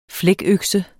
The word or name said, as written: flækøkse